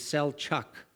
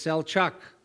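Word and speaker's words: Selçuk: You know that you see that Birch's name is spelled B-E-R-C. Is actually not a pure C. It needs the tail. And the pronunciation of the modern location of Ephesus is Selchuk. It's Birch, and it's Selchuk.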